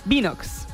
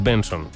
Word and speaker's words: BNXN: Binox. – No. – Vaya. – No es Binox ni es. Bueno, es Benson. – Benson. – No sé por qué es Benson. BNXN se pronuncia Benson.